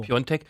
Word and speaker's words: Piontek: Auch der Pole vorne, Piontek, richtig ausgesprochen? Bravo, ja, bravo. Piontek,